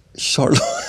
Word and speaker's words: Charlotte: Charlotte, ska man uttala det så också? – Ja, men, ja, för det är ju Charles, det är ju det engelska.